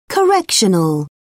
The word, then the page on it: correctional: British English